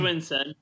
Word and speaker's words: Winstead: It's Winstead.